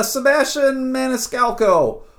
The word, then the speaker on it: Sebastian Maniscalco: But Sebastian Maniscalco.